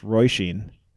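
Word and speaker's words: Róisín: It's a statewide story, I guess, but I believe it's pronounced Roisin. It's R-O-I-S-I-N.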